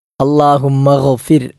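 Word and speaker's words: അല്ലാഹുമ്മ ഗ്ഫിർ (اللهم اغفر): ഒന്നാമത്തേത് അല്ലാഹുമ്മ ഗ്ഫിർ, അവിടെ ഗൈനാണ്, പുള്ളിയുള്ള ഗൈനാണ്. തൊണ്ടയുടെ ഏറ്റവും മുകൾ ഭാഗത്തു നിന്നും പുറപ്പെടേണ്ട അക്ഷരമാണ് ഗൈൻ എന്ന അക്ഷരം.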